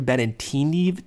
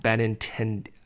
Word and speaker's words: Benintendi: There's no way you pronounce that right. – What is it? – I think it's Benintendi. – What did I say? – I don't know, but not that. – Benintendi.